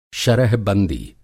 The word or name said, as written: शरह-बंदी